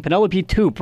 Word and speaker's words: Penelope Toop: And again, you'll be playing Penelope Toop, right? I said that right? – Yes.